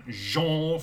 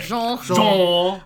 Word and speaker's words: genre: I was very nervous because it's a different genre for me. It's actually how you say it. – Genre. – People say it with a hard J.